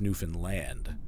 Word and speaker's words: Newfoundland: Newfoundland, it's not Newfoundland, it's Newfoundland.